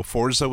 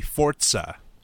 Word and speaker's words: Forza: I mean, I'm not a huge racing person, so Forza was never up my alley. Excuse me, Forza. – Thank you. – My bad. I got to pronounce that correctly.